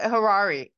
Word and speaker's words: Harari: And they're also, they got a bunch of the, like, Ural Haraldi. How do you say his name? – No, he's a big topic on the show. Noah Harari.